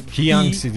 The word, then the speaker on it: Jiangxi: Jiangxi diye okunur.